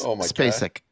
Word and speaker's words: Spacek: How did I say it? I said Spacek. Yeah, Spacek. – It's Spacek.